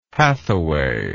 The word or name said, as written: hathaway